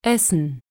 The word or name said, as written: essen